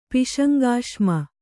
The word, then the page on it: ಪಿಶಂಗಾಶ್ಮ: Kannada; piśaŋgāśma